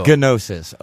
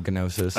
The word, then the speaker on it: Gnosis: Gnosis. Oh, Gnosis. – Oh, Gnosis. – Yeah. – With a soft G.